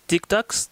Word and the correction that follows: tuk-tuk: tuk tuks, tuk-tuk.